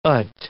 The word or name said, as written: ut